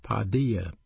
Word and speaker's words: paideia: The word chasten is a Greek word pronounced paideia, P-A-I-D-E-I-A, paideia.